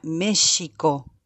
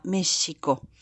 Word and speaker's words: México: Porque mm, en realidad el sonido original, o sea, el topónimo México, eh, proviene de una voz náhuatl que se dice que se eh, pronuncia México, con la sh, muy suavecita, México, que quiere decir, la palabra México en náhuatl quiere decir lugar en el ombligo de la luna.